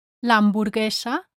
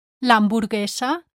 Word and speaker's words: hamburguesa: Number one, the letter U is silent in the cluster G-U-E, ge. For example, in previous lessons we saw the words l'hamburguesa, the hamburger, l'hamburguesa, and Miguel, Michael, Miguel. Rule number two says, the letter U is silent in the cluster G-U-I, gi.